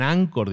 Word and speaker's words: Anchor: Bueno, pues tiene también herramientas y una de ellas que la compró hace unos años se llama Anchor se pronuncia Anchor, se escribe Anchor, es un ancla, digamos.